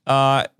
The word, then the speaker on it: Krull: With Peter, I think it's Krull. – Krull.